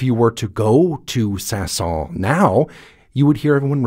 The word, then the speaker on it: Saint-Saëns: When I'm talking about the French composer Camille Saint-Saëns, I often hear it pronounced without an S, and I hear it sometimes pronounced with an S at the end, Camille Saint-Saëns.